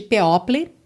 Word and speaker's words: people: People, sempre importante verificar a pronúncia dessa palavra, né? Que se escreve P-E-O-P-L-E, e não tem nada de people, tá?